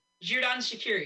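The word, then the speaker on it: Xherdan Shaqiri: You're mispronouncing his name. – Zerdan. – What is it? – Zerdan. – No, it's Zerdan. Zerdan Shaqiri.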